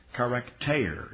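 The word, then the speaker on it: charakter (χαρακτήρ): And it's the way you'd say it in Greek is it starts with a key, charakter. It's tau, eta, rho, and you pronounce eta, a, long a, charakter, which in the King James translators translated it express image.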